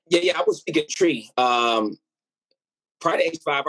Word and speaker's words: Twi: but but Tree, from what I know, is, is one of the uh, the major languages. Yeah, TWI pronounced tree.